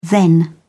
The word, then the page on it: then: British English